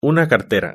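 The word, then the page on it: la cartera: Spanish (Spain)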